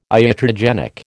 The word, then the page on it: iatrogenic: American English